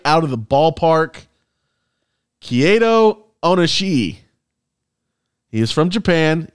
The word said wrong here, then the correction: Kaito: Let me spell it for you. K-A-I-T-O. That's the first name. – K-A-I-T-O. Yeah. Okay, Kato makes sense. – O-N-I-S-H-I. – O-N-I-S-H-I.